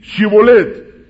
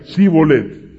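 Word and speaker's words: Shibboleth: Si él respondía no, entonces le decían: ahora pues, di Shibolet. Y él decía, Sibolet. Y entonces, al no pronunciarlo correctamente, lo tomaban.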